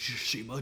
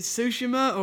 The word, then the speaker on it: Tsushima: Is it Tsushima or Tsushima? Wait, no. Tsushima or Tsushima? – Ghost of Tsushima. I think you said Tsushima? – It right. Tsushima? I think you're saying it right.